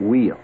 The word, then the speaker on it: Weil: Some people pronounce it Wheel.